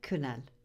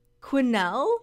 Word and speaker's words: Quesnel: No, they're actually with the one in, I don't really know how to pronounce the where it is, but it's in Quesnel. – Okay. – Do you know where Quesnel. – Quesnel? – Yeah. – Quesnel. – Quesnel? – Yes.